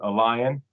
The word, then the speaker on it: LAION: There's a database called Alion, which is spelled L-A-I-O-N.